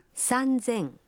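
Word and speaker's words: санзен (三千): Но и здесь произношение меняется. «Три тысячи» будет звучать как «санзен».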